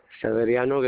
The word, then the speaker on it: Severiano: Severiano Guerrero. – All right, can you spell it for me? – S-E-V-E-R-I-A-N-O.